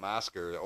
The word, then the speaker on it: Oscar: And down here, it's not spelled the same way as my name, Oscar, O-S-C-A-R. It's spelled O-S-K-A-R, Oscar.